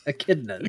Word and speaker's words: echidna: How do you say it? – Enchiladas? – Enchilada? What is it? – Echidnas. – Echidna.